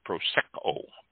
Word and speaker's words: Prosecco: Prosecco, pronounced Prosecco. Prosecco. Prosecco. The accent on the sec.